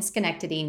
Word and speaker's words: Schenectady: Um, Mary Beth graduated in 1961 and eventually landed a job as a nursing assistant in Ellis Hospital in Schenectady, New York. Now, that one I do know how to pronounce, even though it's definitely not spelled how it sounds.